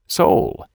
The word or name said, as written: sole